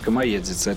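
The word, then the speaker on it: Камаедзіца: для справки мы, наверное, сообщим о том, что слушатели, наверное, не знают, что такое комоезица название, да, по-русски это звучит э, масленица, а по-белорусски это произносится комоедзица.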